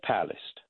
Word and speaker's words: Palast: But the first thing I want to do, Carl, is you had a guest on your show yesterday, Greg Palast.